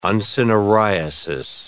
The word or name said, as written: uncinariasis